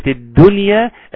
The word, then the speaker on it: الدنيا: بحافظ على الشدة اللي في الدال، والشدة أنه حرف شديد، والتشديد أنه حرف مشدد، يبقى حاجتين في الدال في كلمة الدنيا، أنه حرف شديد، الدال أصلا حرف شديد، ثم انه حرف مشدد كما لو يعني هو حرفين ولكن بننطقه حرف واحد تقيل مثقل.